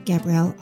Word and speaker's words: Gabrielle: You can also follow me on Twitter to get updates about new podcasts and more at Gabrielle Author.